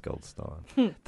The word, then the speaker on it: Goldstein: He was here shooting, and I'm like, oh, I grew up in Goldstein. He's like, actually, it's pronounced Goldstein.